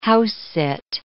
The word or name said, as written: house-sit